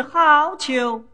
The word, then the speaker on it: 好逑: 窈窕淑女，君子难求。 – 哎，丫鬟，分明是好逑，怎么念成难求了？ – 难求。